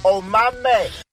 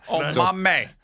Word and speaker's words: Omameh: And so he pronounced the name for us. – Omameh. – Do we have to say that every time that way? – Omameh. – That's how you say the guy's name.